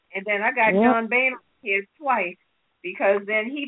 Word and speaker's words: Boehner: And then Boehner, how you pronounce it? – Boehner.